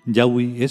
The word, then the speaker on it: Yahweh: Yahweh es como se debe pronunciar el nombre Yahvé.